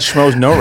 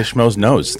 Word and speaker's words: Schmoes Know: And you got Schmoes No right. You didn't say Schmoes Knows.